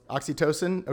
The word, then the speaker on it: oxytocin: I did read some scientists this week, but there's this hormone called oxycontin. I think it's pronounced oxycontin. Oxytocin. Okay, thank you. Yep, oxycontin is the drug. Thank you very much. I knew I was mispronouncing that.